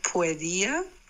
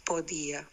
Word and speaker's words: podía: Podía, then it's podía, P-O, podía.